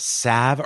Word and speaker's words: salve: There is no better, and I never know how to say this, salve or sauve Or S-A-L-V-E. – Salve.